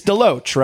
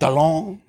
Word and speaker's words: DeLoatch: No, it's DeLoatch, right? – Yes. – Yeah, it's easy. It seems like an easy name to say, but historically people have said it wrong, right? – Yeah, yeah. – They try to make it like more European or like DeLong. – The Loatch is the one I hear the most. Like the OA vowel sound is not common in the English language, apparently.